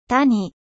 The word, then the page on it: vallée: French